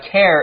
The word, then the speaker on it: tear: Quick note, tear is spelled T-E-A-R, but it's pronounced tear. It's not the same as T-E-A-R, tear, which is what you cry. So be careful with that. Tear is like a rip or a hole. Tear is what you cry. Same spelling, different pronunciation.